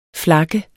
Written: flak

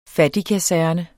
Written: fattigkaserne

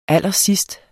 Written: allersidst

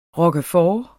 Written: roquefort

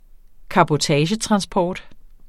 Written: cabotagetransport

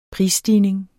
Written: prisstigning

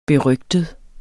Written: berygtet

